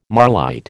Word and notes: marlite: American English

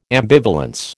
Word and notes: ambivalence: American English

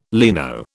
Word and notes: leno: British English

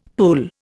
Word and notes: pull: Indian

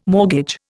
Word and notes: mortgage: Indian

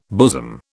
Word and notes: bosom: British English

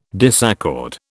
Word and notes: disaccord: British English

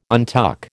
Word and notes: untuck: American English

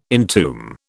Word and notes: entomb: British English

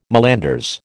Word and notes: malanders: American English

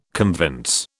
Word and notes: convince: British English

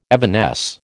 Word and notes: evanesce: American English

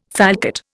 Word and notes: falcate: Indian

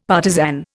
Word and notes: partisan: Indian

